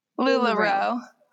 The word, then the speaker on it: LuLaRoe: LuLaRoe. – So I'm just saying it too slow. Yeah. You gotta say it faster.